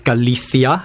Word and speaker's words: Galicia: Then you went to Galicia. Am I saying that right? – Galicia, yeah.